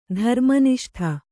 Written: ಧರ್ಮನಿಷ್ಠೆ

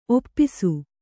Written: ಒಪ್ಪೈಸು

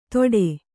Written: ಥೋಡೆ

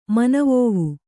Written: ಮನವೋವು

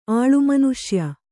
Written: ಆಳುಮನುಷ್ಯ

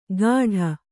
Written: ಗಾಢ

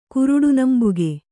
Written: ಕುರುಡುನಂಬುಗೆ

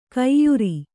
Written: ಕೈಯಾರು

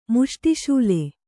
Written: ಮುಷ್ಟಿಶೂಲೆ

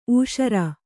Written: ಊಷರು

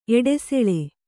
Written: ಎಡೆಸೋಲು

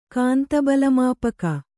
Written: ಕಾಂತಬಲಮಾಪಕ

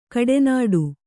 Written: ಕೆಡೆನುಡಿ

